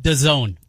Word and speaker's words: DAZN: It's pronounced DAZN.